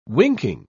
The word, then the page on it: winking: British English